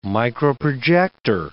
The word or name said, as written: microprojector